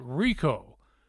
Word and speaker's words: Ricaud: There was a friend of mine, his last name was Rico, R-I-C-A-U-D. He pronounced it Rico.